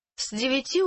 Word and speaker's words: с девяти: Did you hear the z sound at the beginning of that? – Скажите еще раз. – From nine in the morning. – С девяти утра.